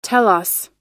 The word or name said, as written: telos